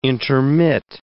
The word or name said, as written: intermit